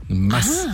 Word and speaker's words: Maß: Das ist eher so, die Betonung ist auf dem S, also eine Maß. – Mass. Ah. Ach, kurz.